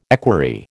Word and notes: equerry: American English

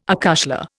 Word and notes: acushla: Indian

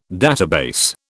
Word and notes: database: British English

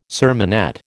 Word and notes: sermonette: American English

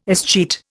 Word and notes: escheat: Indian

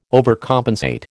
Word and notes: overcompensate: American English

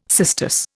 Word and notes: cistus: Indian

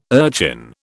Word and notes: urchin: British English

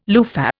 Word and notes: loofah: Indian

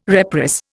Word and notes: reprice: Indian

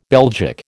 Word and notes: Belgic: American English

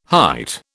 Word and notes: hight: British English

